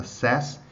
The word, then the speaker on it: says: Uh, se você for falar na terceira pessoa, não, não se esqueça de falá-lo com a pronúncia aberta, says.